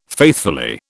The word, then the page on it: faithfully: British English